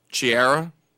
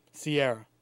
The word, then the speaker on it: Ciara: C-I-A-R-A. – Chiara? – Sierra. – Sierra? – C-I-A-R-A.